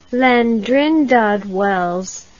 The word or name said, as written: Llandrindod Wells